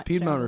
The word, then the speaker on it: Piedmont: Piedmont refers to a region of the United States.